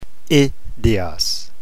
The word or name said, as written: idios